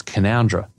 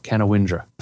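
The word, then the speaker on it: Canowindra: And by the way, it's Canoundra, not Canawindra. I thought you guys were used to places that don't sound anything like this spelt.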